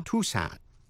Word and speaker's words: Tucson: Và một từ ngữ nữa, Tucson. T-U-C-S-O-N, Tucson. T-U-C-S-O-N, âm C câm, đó là một thành phố ở Arizona. Vậy tóm lại những chữ như indict, conscience, Connecticut, Tucson, trong đó âm C câm.